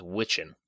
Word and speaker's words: Wiccan: but they pronounced W-I-C-C-A-N as Witchin.